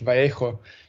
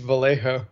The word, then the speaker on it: Vallejo: I would think, because I speak Spanish, Vallejo, but I think it's Vallejo.